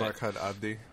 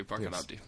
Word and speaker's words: Barkhad Abdi: Barkhad Abdi. – Yes, thank you, Barkhad Abdi.